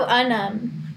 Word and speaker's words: Unum: Oh, Unum.